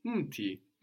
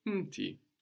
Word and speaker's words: mti: So this is really muti, but the u completely gets swallowed up and isn't written either, no? It's written mti, mti. Also, when you pronounce words like umji, mti, it's very important not to put a small vowel sound, which would come out very naturally to English speakers. It's mti, not mti. If you say mti, for example, it might sound like you are pronouncing the plural, which is miti.